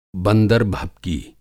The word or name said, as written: बंदर-भभकी